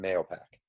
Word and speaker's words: Mahopac: All right, so I just want to say, I pronounce it Mayopac. However,